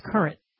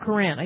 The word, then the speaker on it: Courant: And I think it's Courant. The Hartford Courant. I used to read this paper. You think I would know how to pronounce it.